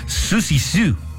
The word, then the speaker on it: Siouxsie Sioux: Se pronuncia, perdóneme usted, Susie Sue. Ahí está más fácil. Líder de Siouxsie Sioux and the Banshees. Siouxsie Sioux.